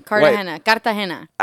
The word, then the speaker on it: Cartagena: Cartagena. – Ah, there we go. That was the role I wanted. I wanted that R roll.